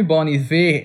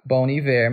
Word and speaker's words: Bon Iver: Ah, o nome Bon Iver é do francês Bon Hiver, que é com H, que é bom inverno. Então eu acho que a pronúncia vai ficar Bon Hiver mesmo.